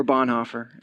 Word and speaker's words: Bonhoeffer: His name is Dietrich Bonhoeffer. Or Bonhoeffer, as most people like to pronounce it, Dietrich Bonhoeffer.